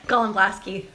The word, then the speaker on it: Golemblaski: Golemblaski.